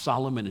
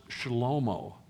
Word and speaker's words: Shlomo: Solomon's name is not pronounced Solomon in Hebrew. It's pronounced Shlomo.